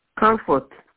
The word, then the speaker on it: Comfort: My name is Comfort. – Can you spell that for accuracy, please? – C-O-M-F-O-R-T.